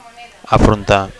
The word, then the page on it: afrontar: Catalan